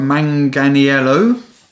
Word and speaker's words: Manganiello: Joe Manganiello.